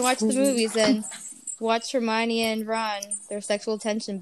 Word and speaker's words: Hermione: Hermione, yeah.